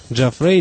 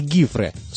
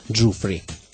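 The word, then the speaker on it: Giuffre: Многие ее носители в США произносят ее по-разному. Джафрей, Джуфре и даже Гуфре и Гифре. Собственно, Гифре – это подлинное итальянское чтение. Сам музыкант когда-то положил конец этим разногласиям, прямо указав, что в его семье фамилия читается Джуфри.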